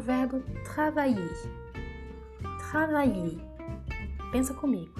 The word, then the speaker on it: travailler: O que vem antes desse E é o som do vai. Travailler. Então, vai, a sílaba forte.